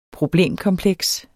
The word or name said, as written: problemkompleks